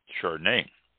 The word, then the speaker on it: Chardonnay: Okay, we just talked about Chablis, Chardonnay. C-H-A-R-D-O-N-N-A-Y, pronounced Chardonnay. S-H-A-R, Char, Duh, D-U-H, Nay, N-A-Y.